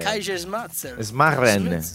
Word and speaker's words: Kaiserschmarrn: Kaiserschmarrn.